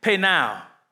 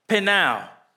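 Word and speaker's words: penao (πεινάω): When we look at these words, hunger and thirst in the Greek, hunger is actually pronounced in the Greek, penao, penao.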